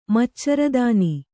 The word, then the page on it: ಮಚ್ಚರದಾನಿ: kannada